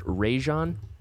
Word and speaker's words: Rajon: So it's pronounced Rajon, not Rajon. I know that the entire media is having a hard time with that, but we're going to be on the cutting edge. – I've also heard Rajon. – So Mason Ireland asked him to say his first name. – Okay. – And he said, Rajon. – Rajon. – Like, very clearly, like, two syllable, Rajon.